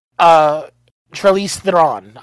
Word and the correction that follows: Charlize Theron: Charlize Theron.